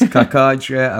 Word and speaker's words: Cacace: The OGs will remember Cacace and um, how much we struggled to figure out how his name's pronounced. Liberatore Kakake. C A C A C E. Is it Kakake? Is it Chachache? – Kasache. Yeah. Sasase. It's Kakache. Apparently, um, we used to call him Libby.